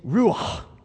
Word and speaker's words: ruach: In the Hebrew here, that word for breathe is ruach. Everybody say that. Ruach. You kind of clear your throat. Sounds like you can't. Ruach. I know I'm not pronouncing it right. We're going to call it ruach.